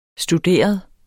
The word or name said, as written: studeret